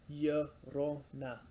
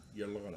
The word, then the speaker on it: Llorona: At La, and then L in Spanish is silent, so it's like pronounced with a Y. So Llorona. – Llorona.